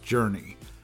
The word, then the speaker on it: JRNY: Nautilus had rolled out a subscription platform called JRNY, which I guess is pronounced Journey.